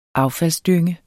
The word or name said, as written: affaldsdynge